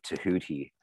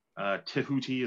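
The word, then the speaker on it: Tehuti: Both, I mean, we have this connection, you and I, as sort of devotees of Tahuti. You prefer the Jehuti pronunciation, correct? – It just kind of depends. Jehuti has a nicer sort of mouth feel.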